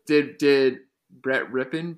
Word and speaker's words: Rypien: I hope I'm saying that correctly. It's Rypien. – Rypien. Brett Rypien Sorry, I'm I'm not familiar with irrelevant Boise State quarterbacks.